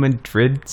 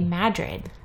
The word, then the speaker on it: Madrid: Because in Iowa, any town that's spelt Madrid, you say Madrid. – Well, that's the name of that town. That town's name is Madrid.